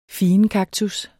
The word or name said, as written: figenkaktus